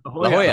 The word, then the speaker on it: La Jolla: Am I pronouncing La Jolla? – La Jolla.